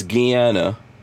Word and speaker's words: Guiana: That's how it's pronounced, by the way. It's Guiana, and there's French, which I think is called Suriname now, right?